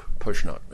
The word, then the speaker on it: Pozniak: There are a couple outside of that period, and years after the fact and after Pozniak testified, I'm saying it phonetically, his name's actually pronounced Pushnock, Mr. Pushnock, the president, but when you read it, you wouldn't know that's who it is.